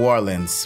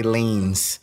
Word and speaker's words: Orleans: so we locals say New Orleans. We never say leans.